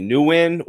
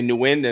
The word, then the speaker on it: Nguyen: His first name is Dat, and last name, some people pronounce it differently, Nguyen or Win. But every time I talk to him, I say Nguyen, and he doesn't correct me, so I'm going to go with that.